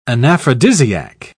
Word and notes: anaphrodisiac: British English

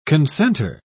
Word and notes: consenter: British English

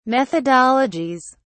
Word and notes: methodologies: British English

